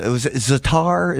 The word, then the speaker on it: za'atar: But the grape leaves and the za'atar, is that what it's? – Za'atar? – Yeah, za'atar. Right. Is that how you say it? – Yes.